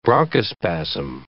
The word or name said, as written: bronchospasm